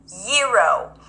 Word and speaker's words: gyro: Row, gyro. You can also pronounce it with a zh sound. Round your lips a bit, bring the tongue up, vibrate the sound, zh, as in vision, gyro, gyro, gyro, gyro.